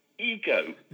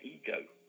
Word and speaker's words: Igo: called Ego, spelt I-G-O. Now, I wouldn't have known it was spelled I-G-O if you hadn't pointed that out, Mark. Again, how have they decided that's pronounced Ego?